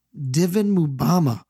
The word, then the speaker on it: Divin Mubama: He uh Mumbapa. – How do you say his name? So it's it's Divin uh, Mubama. – Divin Mubama.